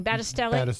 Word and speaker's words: Battistelli: Battistelli.